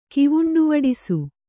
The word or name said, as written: ಕಿವುಂಡುವಡಿಸು